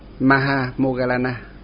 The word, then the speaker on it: Mahamoggallana: Ngài có tiếng Phạn, phiên âm phát âm tiếng Phạn là tôn giả Mahamoggallana.